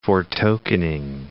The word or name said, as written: foretokening